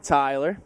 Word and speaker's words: Tyler: Tyler. – Tyler, can you spell that? – T-Y-L-E-R.